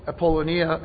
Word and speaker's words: Apollonia: It's Amphipolis, Apollonia, and Thessalonica.